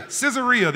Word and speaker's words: Caesarea: I'm hoping I'm saying that right. But it's almost like Caesarea. Caesarea, there it is.